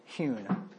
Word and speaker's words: hewn: Is that pronounced hone or hewn? – I say hewn. – I'd always heard hewn. – Hone would be H-O-N-E. – What do you do when you hone out a cylinder? – H-O-N-E. – H-O-N-E.